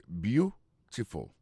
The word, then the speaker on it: beautiful: Gardez pour voir que l'accent tombé sur le premier syllabe là. That was beautiful.